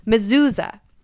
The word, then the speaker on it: mezuzah: Mezuzah, with a mini mezuzah.